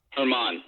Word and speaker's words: Germán: Say what's how do you say this guy's name? Domingo Germán. – Herman. Yeah. Herman. Yep, you got it.